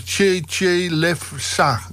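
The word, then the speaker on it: Trzetrzelewska: Dus het staat er trouwens wel leuk op Wikipedia, achternaam uitgesproken als Cheche Lefsa.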